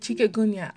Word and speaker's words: chikungunya: Or chikungunya. That's it, chikungunya. I had a particular fun time trying to find out how to say that word. It's chikungunya.